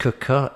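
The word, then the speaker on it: Quooker: The first one is Cooker, spelt Quicker, pronounced Cooker.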